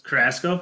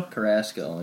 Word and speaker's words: Carrasco: And then you got Carlos Caruso. I think that's how you spell it. I don't know how you say his last name. – Carrasco? – Carrasco, yeah. – The pitcher for the Indians? – Carrusco.